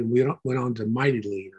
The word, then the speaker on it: Mytilene: So it says that he met us at Assos and took him aboard and went on to, looks like Mytilene, but it's Mytilene, the way you pronounce it.